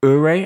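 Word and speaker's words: Ouray: The Parley Ure, I think that's how Ure, you pronounce it. – It's O-U-R-A-Y. It's actually a small town in Colorado, in the San Juan Mountains in Colorado.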